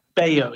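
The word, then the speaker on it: Bello: Bayo, is that how you pronounce it? – Bayo, yeah.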